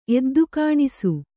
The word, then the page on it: ಎದ್ದುಕಾಣಿಸು: kannada